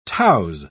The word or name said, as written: touse